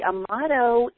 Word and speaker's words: Amato: Amato in Italy is like Smith in the United States, and hardly anyone pronounces it correctly, so well done.